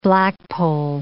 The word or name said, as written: blackpoll